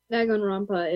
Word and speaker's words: Danganronpa: And then I only got to see half of Danganronpa, I think is called, or well, pronounced. I don't really know how to pronounce it. – Danganronpa is how you pronounce it.